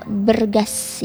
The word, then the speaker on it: BE29AS: Dan ini cara bacanya ternyata bergas ya, agak ala-ala ya.